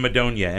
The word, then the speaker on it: Madonia: Dan Madonia, M-A-D-O-N-I-A.